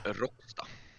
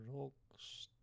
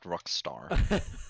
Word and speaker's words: Råcksta: It's in Råcksta. – Råcksta. – I'm living Råcksta. Want Råcksta. – Me to spell it for you? Råcksta. No, not Rockstar.